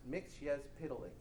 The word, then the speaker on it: Mxyzptlk: It's a Superman villain called, well, if you want to know, it's pronounced Mixyezpitalik.